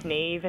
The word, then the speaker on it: Niamh: Yeah, it's Niamh. It's a difficult one to pronounce.